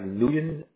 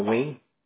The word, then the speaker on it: Nguyen: N-G-U-Y-E-N. – E-S. How do you pronounce that? That's different. – You can pronounce it like Nugen or Wayne.